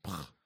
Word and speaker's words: Pugh: Florence Pugh. That's how you say it.